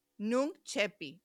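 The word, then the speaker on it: nunc coepi: es en latín, pero ¿cómo se pronuncia sin la pronunciación en latín? Es nunc, N-U-N-C, coepi, nunc cepi, ahora comienzo.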